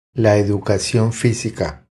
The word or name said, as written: La educacion fisica